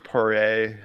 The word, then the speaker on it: Poirier: I think it's Poirier. I think the R is silent, but he got the second bout.